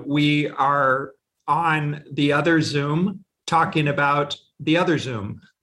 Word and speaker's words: Zum: As you rightly pointed out, the name of the company is Zoom. It's spelled Z-U with a line on the top and M.